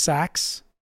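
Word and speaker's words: seax: The Saax, pronounced Sax, is the knife from which the Saxons took their name.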